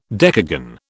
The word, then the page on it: decagon: British English